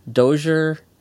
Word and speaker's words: Dozier: I know the creator of the Batman series, I don't know how to say his name, William Dozier. – Dozier. Yeah.